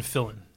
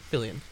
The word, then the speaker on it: Fillion: Is it Nathan Fillion or Nathan Fillon? – It says Fillion. – Fillion. – So. – Oh boy, I've been saying that wrong. – Yeah, there's years. An I. There's an I in there.